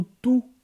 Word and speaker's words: tu: O tu, ele se escreve da mesma maneira, T-U, só que se pronuncia tu.